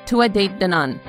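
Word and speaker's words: Tuatha Dé Danann: One of them I just found out is the classification, Tua de Danann.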